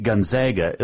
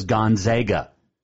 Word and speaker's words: Gonzaga: And if you're going to pick Gonzaga, at least know how to pronounce the name. It is Gonzaga. It's not Gonzaga. They're not the Zogs. It's Gonzaga. Really, they're not the Zay. They're the Bulldog, right? But it's still Gonzaga. I'll give you my pick coming up.